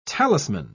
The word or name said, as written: talisman